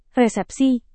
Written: resepsi

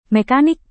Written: mekanik

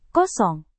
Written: kosong

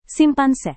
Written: simpanse

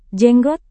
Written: jenggot

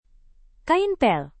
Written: kain pel

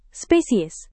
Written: spesies

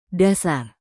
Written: dasar

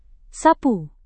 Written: sapu

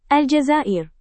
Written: Aljazair

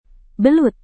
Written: belut

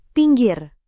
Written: pinggir

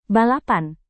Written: balapan

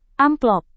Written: amplop

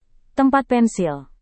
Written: tempat pensil

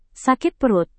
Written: sakit perut

